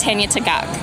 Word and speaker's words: Tanya Tagaq: Tanya Tagaq.